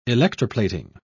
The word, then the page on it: electroplating: British English